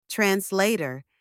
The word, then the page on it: translator: anglais